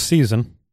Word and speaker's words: saison: So in the French, it's pronounced season.